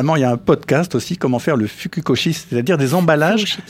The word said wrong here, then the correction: furoshiki: Je prononce mal Je crois que c'est furoshiki, mais bon. – ah, furoshiki, voilà, pardon.